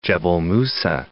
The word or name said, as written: Musa, Gebel